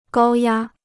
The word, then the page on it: 高压: gāo yā